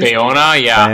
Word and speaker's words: Bayona: J.A. Bayona, I'm guessing it is? – Bayona, yeah.